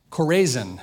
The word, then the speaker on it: Chorazin: It's pronounced Chorazin, kind of like Chorazins, but not really.